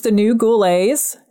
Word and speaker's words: Ghoulets: The name of my band is The New Ghoulets, and that's spelled G-H-O-U-L-E-T-S. And it is named after our patron saint, Robert Goulet.